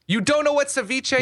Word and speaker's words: Ceviche: Ceviche.